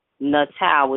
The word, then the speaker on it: Natal: You can contact me, look me up under my full name on Facebook, Francine Elizabeth Natal,